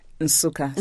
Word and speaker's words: Nsukka: You were born in a town called Nsukka. Am I saying that right? – Nsukka. Nsukka. So the N is hummed.